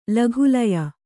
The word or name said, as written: ಲಘುಲಯ